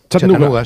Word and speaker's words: Chattanooga: ¿Y se pronuncia así en Chattanooga? – Chattanooga. Sí. Chattanooga City.